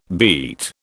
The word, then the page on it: beat: British English